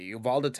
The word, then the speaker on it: Uvalde: Is that how you pronounce it? – Uvalde. – Okay, thank you. Uvalde, Texas, where some piece of shit slaughtered a lot of kids.